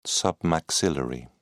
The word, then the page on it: submaxillary: British English